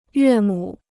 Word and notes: 岳母: yuè mǔ

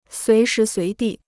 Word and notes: 随时随地: suí shí suí dì

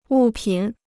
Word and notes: 物品: wù pǐn

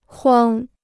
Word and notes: 筐: kuāng

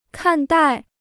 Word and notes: 看待: kàn dài

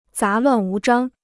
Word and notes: 杂乱无章: zá luàn wú zhāng

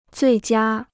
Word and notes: 最佳: zuì jiā

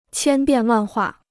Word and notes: 千变万化: qiān biàn wàn huà

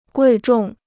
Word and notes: 贵重: guì zhòng

